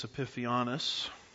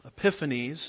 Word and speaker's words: Epiphanes: Yeah, depends on which syllable gets the emphasis. I call it Antiochus Epiphanius. If you want to call him Antiochus, that's fine. Or Epiphanes, that's fine. Or Epiphanius, whatever.